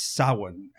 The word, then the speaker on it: Samhain: It's a Celtic word. It's Samhain, as he says it. – Samhain. Yeah, we get the proper Celtic pronunciation of it.